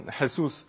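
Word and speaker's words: Jesús: And that's okay because that's what US English native speaker would expect, but obviously that's a Mexican footballer, so it's pronounced Jesus.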